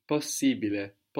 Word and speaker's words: possibile: So A-B-L-E becomes A-B-I-L-E, and I-B-L-E becomes I-B-I-L-E. – Abile, ibile. – So possible becomes Possibile, possibile. – But we also pronounce the two S's. – Possibile.